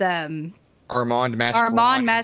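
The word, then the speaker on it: Armand Mastroianni: It's Armand Mastrolani. – I said it when I interviewed Russell Todd, and he was like, that's right. But who knows? He might have just been being nice. – Armand Mastrolani.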